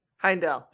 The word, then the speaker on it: Heimdall: Heimdall.